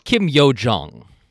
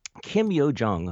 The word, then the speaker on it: Kim Yo Jong: And first of all, how do you pronounce her name? Is it Kim Yo? – I thought you were going to ask how you pronounce my name, and this is a good time to remind all our listeners. – Kim Yo Jong.